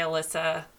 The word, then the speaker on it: Alissa: I'm like very active there, and that's at Life by Alissa a L I S S A.